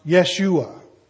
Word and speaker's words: Yeshua: In Hebrew, Joshua's name would have been pronounced Yeshua.